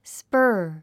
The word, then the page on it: spur: spə́ːr; スパァ